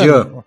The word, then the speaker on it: Jean: Eh, será que o nome dele é Jean ou Jean? É Jean.